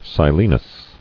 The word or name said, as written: Silenus